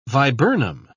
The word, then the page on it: viburnum: British English